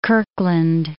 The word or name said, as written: Kirkland